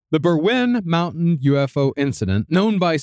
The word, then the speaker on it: Berwyn: Well, no, I saw it's Welsh, so it's going to be a strange pronunciation. – It's Berwyn.